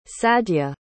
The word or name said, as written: sadya